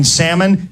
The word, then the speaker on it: salmon: If you go to the restaurant and the waitress pronounces the L in salmon, it's not going to be a good meal.